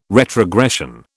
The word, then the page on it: retrogression: British English